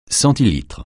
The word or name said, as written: centilitre